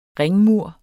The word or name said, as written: ringmur